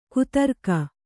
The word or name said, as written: ಖದಿರಕ